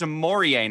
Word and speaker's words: Omoruyi: And we called him Omoruyi, and then we were told that he was like, "No, no, you're saying it wrong. It's a Mori." And we were like, "Oh, okay." So we called it a Mori, and then we were told, "No, no, he says it's a Morie now."